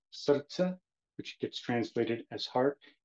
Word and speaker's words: srce: S-R-C-E. So the way you pronounce three consonants in this specific language is you have to treat the R as if it has an U or a vowel in front of it, so it's pronounced srce.